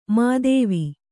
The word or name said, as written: ಮೋದವಿ